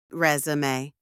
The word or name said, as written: resume